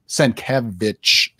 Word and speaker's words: Sienkiewicz: But Bill Sienkiewicz absolutely killed it. He's still killing it to this very day, man. Sienkiewicz, it's in his Twitter. That's how you say it.